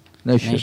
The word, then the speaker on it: Nature: Nature, sí.